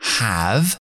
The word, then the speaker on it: have: Entonces, esta palabra have, have, como es una sílaba cerrada, la A es muy, es la A muy abierta, have, have, y la V, v, v, v, no es bilabial, no cerramos, no es como la B, es como la F, pero con voz, have, have.